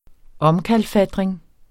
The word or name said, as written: omkalfatring